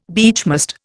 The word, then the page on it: beechmast: Indian